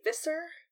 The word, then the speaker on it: visor: Applegate has said many times that visor is pronounced, that's supposed to rhyme with kisser. So it should be visor, but I have never said it that way.